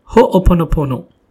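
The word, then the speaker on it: Ho'oponopono (Hoʻoponopono): Ho'oponopono. I'll spell that for you. It's H-O-O-P-O-N-O-P-O-N-O. It's called Ho'oponopono. The mantra goes like this.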